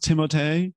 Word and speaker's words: Timothée: His name actually is pronounced Timote.